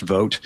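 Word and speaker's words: Vought: I also don't like the fact that his name is pronounced Vogt because it makes me worry about what they have in store for the midterms.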